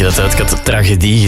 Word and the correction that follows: tragedie: gezegd. Jan uit Merkspas vroeg zich al af of hij het al heel zijn leven fout uitspreekt en ik het juist heb. Nee, tragedie, niet tragedie.